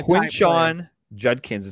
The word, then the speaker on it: Quinshon Judkins: So uh, Quinshon Judkins is how you pronounce the name.